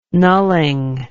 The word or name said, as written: nulling